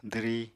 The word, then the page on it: dwri: apatani